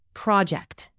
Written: project

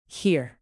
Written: here

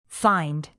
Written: fined

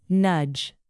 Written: nudge

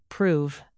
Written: prove

